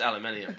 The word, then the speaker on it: aluminium: Like, it's aluminium, but like... – But Americans say aluminum.